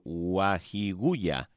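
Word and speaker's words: Ouahigouya: Usted, padre Venceslao, es de la diócesis de Ouaghigouya, en el norte de Burkina Faso.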